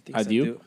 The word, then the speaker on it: adieu: Abdu. I think that's how they say it in the French. – Adieu.